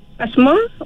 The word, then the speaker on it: Hasmah: Siti Hasmah Osman juga. – Siti. Macam mana ejaan tu? Asma A-S ke ni? – H-A-S-M-A Hasmah Osman, O-S-M-A-N, Siti Hasmah Osman.